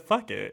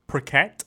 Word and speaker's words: Phuket: It is called Phuket. I said, fuck it. – Yeah, that's how I pronounced it when I first went, because I heard somebody say that. – Phuket? – Phuket.